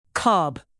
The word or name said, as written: cob